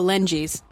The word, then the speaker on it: Lengies: L-E-N-G-I-E-S. – Oh, Lengies.